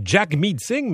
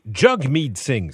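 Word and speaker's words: Jagmeet Singh: Tout le monde vous appelle Jack Meetsing, mais faut le prononcer Jug Meetsing, c'est ça? – C'est ça. J'essaie de promouvoir ça, mais Jesse Jug, ça rime avec hug en anglais, mais Jug, et ça veut dire monde, et meet, ça veut dire ami.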